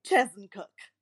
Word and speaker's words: Chezzetcook: And she goes, Well, dear, it's pronounced just the way it's spelled C H E Z Z E T C O O K, Chesncook. anyway, I just I just love it because everybody calls him Chesncook. Nobody knows why. It's not spelled with an N, but anyway.